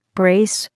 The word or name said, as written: brace